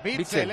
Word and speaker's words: Witsel: Witsel, él lo dice bien. – Con B. Pero es Witsel, Witsel, como si fuera con B.